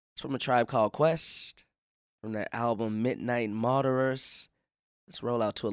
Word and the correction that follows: Marauders: Did I say the name wrong? – I'm not going to say it. – No, fucking say it. – It's Marauders.